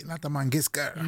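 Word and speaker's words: Lata Mangeshkar: Lata Mangiska. – Yes. Heb ik het goed uitgesproken? Heel goed.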